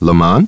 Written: Lamont

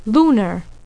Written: lunar

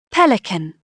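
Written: pelican

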